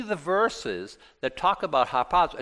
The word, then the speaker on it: harpazo: Now, the Greek word is harpazo. Harpazo. H-A-R-P-A-Z-O.